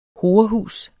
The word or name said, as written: horehus